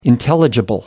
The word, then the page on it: intelligible: American English